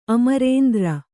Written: ಅಮರೇಂದ್ರ

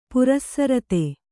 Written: ಪರಿಸ್ರುತೆ

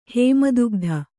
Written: ಹೇಮದುಗ್ಧ

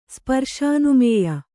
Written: ಸ್ಪರ್ಶಾನುಮೇಯ

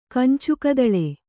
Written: ಕಂಚುಕದಳೆ

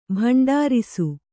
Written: ಭಂಡಾರಿಸು

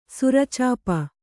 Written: ಸೌರಚಾಪ

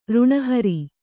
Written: ಋಣಹರಿ

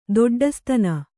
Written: ದೊಡ್ಡಸ್ತನ

